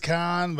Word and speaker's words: Cannes: They say Cannes, Con, but I know. – How do the French say it? – Con. Con. Yeah, Con. They don't pronounce the S.